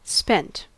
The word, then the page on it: spent: American English